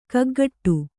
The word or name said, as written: ಕಿಗ್ಗಡೆ